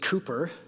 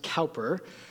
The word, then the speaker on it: Cowper: One of Christian history's most famous poets, William Cowper, and that is pronounced Cooper, not Cowper, was born November 15th, 1731, near London, England.